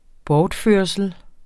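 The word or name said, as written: Bortførslen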